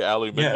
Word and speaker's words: aluminium: We say aluminum. – Aluminum, yeah. – You say aluminium. – Yeah, aluminum. Yeah, I think because of how it's spelled, it should be aluminium.